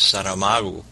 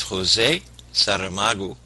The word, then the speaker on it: Saramago: who tells me that the correct way to pronounce this gentleman's name is Saramago. His name is José Saramago, and José Saramago has written two books that we are primarily concentrating on tonight.